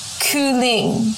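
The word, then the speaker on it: kylling: This is how you say chicken in Danish. Okay, listen up. Kuling. – Cooling? – Kuling. Jury's still out if you pronounce the K or not, because on Google Translate, it doesn't sound like you pronounce the K, but it is spelled with a K. So it's either cooling, yeah, basically cooling, or cooling.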